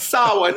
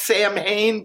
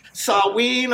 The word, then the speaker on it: Samhain: for the Wicked Mexican spiritual new year, which is Samhain. It looks like it might be pronounced Samhain, but it's actually Samhain or Samhain.